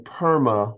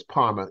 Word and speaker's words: Parma: It's not Perma, it's Parma.